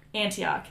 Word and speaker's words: Antioch: Antioch. That's how you say it for sure. – Yeah, that is.